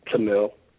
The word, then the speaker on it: Tamil: It's Tamal. Like, it's pronounced T-U-M-I-L, but it's spelled T-A-M-I-L.